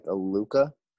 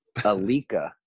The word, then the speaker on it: Alukah: The name looks like Alukah, but we call them Alukah. Is what? Yeah, is how they were pronouncing it from what I heard.